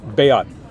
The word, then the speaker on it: Beat: Bayat. – Bayat? – Yeah. – Okay. – Yeah, it's like the word beat. – Ah. – Haldimann. Yeah, yeah. Super, Bayat.